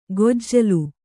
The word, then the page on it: ಗೊಜ್ಜಲು: gojjalu; Kannada